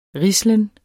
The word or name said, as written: rislen